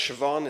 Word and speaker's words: Siobhan: The story behind the book, it's pronounced Siobhan, it's an Irish name.